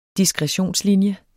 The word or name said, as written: diskretionslinje